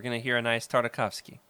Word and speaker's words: Tartakovsky: I'd never clinged on to it as hard as I did with, like, the Clone Wars and especially Gendy Tartakovsky. Oh, man, I'll never say his name right. Okay. You can say it for me, Jared. – Tartakovsky.